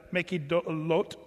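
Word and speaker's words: Makheloth: By the way, when you're saying Hebrew words, I keep trying to remind myself of this, but the THs are often just like a T when you're pronouncing this. So just not that we want to be hoity-toity in our pronunciation, but, you know, that's often. So verse 25, they removed from Haradah and pitched at Mehiloth is kind of the way you'd say that.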